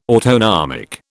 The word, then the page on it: autonomic: British English